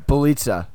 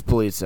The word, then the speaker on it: Bjelica: Nemanja Valencia. You're joking, right? – Bjelica. It's Bjelica. Oh my god, I'm so tired.